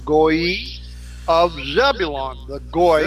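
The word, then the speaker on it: goy: The people, the Goi, is like Goi, which means nation in Hebrew. – Right, yeah. – Since in northern dialects, Sabalin and Zebulon would have been alternate pronunciations of the same name. The Z was replaced by a capital S, and Goi means people in Hebrew. It's G-O-Y, Goi.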